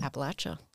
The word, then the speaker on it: Appalachia: Feel like we're ingraining ourselves and just learning more and more about Appalachia. And I said it right. Did you notice? – You did say it right.